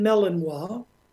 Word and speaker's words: Malinois: Well, my wife and I owned and bred the Belgian Malinois. Some people pronounce the breed Malinois, which is the improper pronunciation.